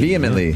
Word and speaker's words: vehemently: NASA has, um, so bad with this word, vehemently, That's I, say, I say that right? Uh, mm-hmm. vehemently. Vehemently, Vehemently is the right way to say that.